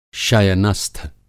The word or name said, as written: शयनस्थ